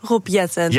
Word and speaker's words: Jetten: Nou, de uitspraak was wel goed, Rob Jetten.